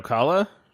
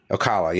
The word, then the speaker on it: Ocala: But it's going to be, how do you pronounce that? Oscala? It was by Tampa, whatever that is. – Are you talking about Ocala? – Ocala, yeah, or, sure.